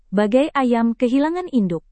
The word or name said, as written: bagai ayam kehilangan induk